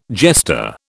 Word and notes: jester: British English

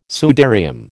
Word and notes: sudarium: American English

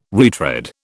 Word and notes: retread: British English

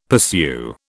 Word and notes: pursue: British English

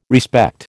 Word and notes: respect: American English

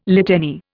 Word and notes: litany: Indian

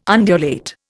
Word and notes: undulate: Indian

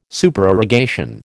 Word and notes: Supererogation: American English

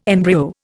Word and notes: embryo: Indian

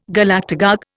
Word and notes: Galactagogue: Indian

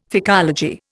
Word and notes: phycology: Indian

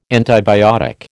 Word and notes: antibiotic: American English

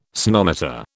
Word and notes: sonometer: British English

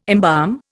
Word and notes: embalm: Indian

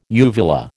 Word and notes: uvula: American English

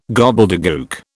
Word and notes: gobbledegook: British English

